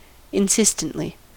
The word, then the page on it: insistently: American English